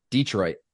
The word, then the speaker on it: Detroit: Detroit, the emphasis on the first syllable.